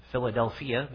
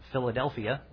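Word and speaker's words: Philadelphia (φιλαδελφία): It's Philadelphia, but if we put the emphasis on the wrong syllable, it's Philadelphia.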